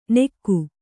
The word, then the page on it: ನೆಕ್ಕು: Kannada